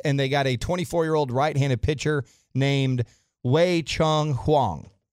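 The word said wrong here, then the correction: Wei-Chieh Huang: Wei Jays, the way they're the pronunciation guide I just got from the Rangers. – Okay, give it to me. What is it? Wei Jay Huang.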